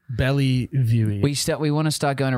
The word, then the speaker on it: Bellevue: Bellevue.